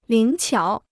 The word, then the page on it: 灵巧: líng qiǎo